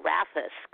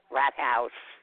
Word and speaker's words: Rathaus: When I went to Queens College, we thought it was the music wing was called Rathus because it's spelled R-A-T-H-A-U-S, and we joked around calling it Rat House. And as it turns out, that's the pronunciation.